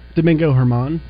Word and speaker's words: Domingo Germán: Domingo Germán.